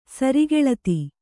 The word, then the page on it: ಸರಿಗೆಳತಿ: sari geḷati